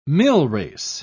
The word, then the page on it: millrace: British English